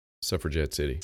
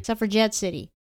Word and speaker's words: Suffragette City: Number 3, Suffragette City. Did I say that right? – Suffragette City. – Suffragette City.